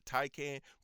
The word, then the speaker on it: Taycan: It's called a Porsche Taycan. They call it Taycan, but it's spelled Taycan, T-A-Y-C-A-N. Yeah.